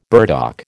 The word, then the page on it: burdock: American English